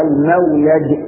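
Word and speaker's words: المولج: المولد كما أنطقها لك هكذا وخير المخرج، بسم الله ولدنا وبسم الله خرجنا وعلى الله ربنا توكلنا.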